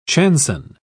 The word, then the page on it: chanson: British English